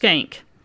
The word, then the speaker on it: Shenck: And by the way, before I get too far in this, the high school is spelled S-H-E-N-C-K, and it's pronounced skank.